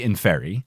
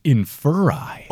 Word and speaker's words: Inferi: In my head, it's Inferi. – Yeah. I would say Inferi. – Okay. – Stephen Fry says Inferi. – Oh, wait, I kind of like that. – Inferi.